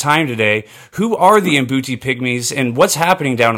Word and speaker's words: Mbuti: Am I saying that right? Is the Mbuti? – Yeah, Mbuti.